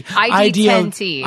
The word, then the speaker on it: ID10T: People in Northern California can come see you at, is it pronounced the Idiot Festival? Is it the ID10T? – ID10T. But it looks like Idiot.